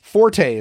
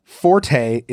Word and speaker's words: forte: If you're discussing someone's forte, as in a strength, the E is silent. Forte is correct only if you're using it as a musical term. So someone's fort. It's my fort. Okay. So you don't say the E. It's not forte.